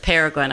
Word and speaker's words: Peregrine: Peregrine. I hope I say it. Yep. Peregrine. Yes. Yeah. And at St. Peter's Chanel, there's a beautiful statue of St. Peregrine, saint for cancer.